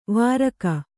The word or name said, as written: ವಾರಕ